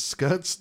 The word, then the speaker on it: Scutt: 13-14 is Scut's Deluge of Despair. I think that was really an ode to me. – Okay. – I know they spell it S-C-U-T-T, but it's really close to.